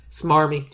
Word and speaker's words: smarmy: Yeah, he's really good at swarmy. Smarmy. – Smarmy. – Smarmy, that's how you say it.